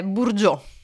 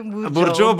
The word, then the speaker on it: Burgio: non si pronuncia all'inglese tipo Bargio, no? – No, è Burgio. – Ah, è Burgio, benissimo. – È Brigitte Burgio.